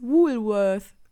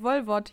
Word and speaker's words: Woolworth: Eigentlich heißt es Woolworth. Ah! oh mein Gott. Wie nennst du das? Ja, auf Deutsch ausgesprochen, halt Wolwort hieß es immer.